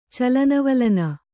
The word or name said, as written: ಚಲನವಲನ